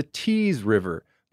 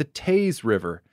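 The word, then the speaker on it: Teays River: I pronounced the Old River as the Tees River. But listener Brent Brash wrote in to let me know that it's actually the Tees River.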